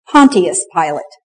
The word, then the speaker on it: Pontius Pilate: Now, we pronounce his name in the English way. If we were faithful to the Greek, he would have been for us down through the ages Pontius Pilate.